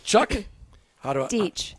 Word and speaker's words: Deech: Or the Florida Sheriff's Youth Ranch, which is why we've got Chuck. – Deech. – Deech. – Oh, rhymes with beach.